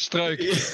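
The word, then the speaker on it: Struijk: Because a foreigner, or because Strike is Dutch. – Oh, he's Dutch. Oh, yeah. Shit, sorry. – We say it's easy, it's just Strike.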